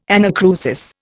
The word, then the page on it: anacrusis: Indian